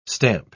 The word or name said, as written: stamp